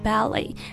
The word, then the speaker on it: ballet: 但是大家刚听到我和 Jenny 的发音发的是 ballet. So the key is that the letter T at the end of the word is silent.